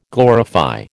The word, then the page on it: glorify: American English